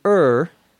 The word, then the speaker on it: err: This is an expression we use to err, E-R-R, sometimes pronounced to err. It comes from the word error, E-R-R-O-R, which means a mistake.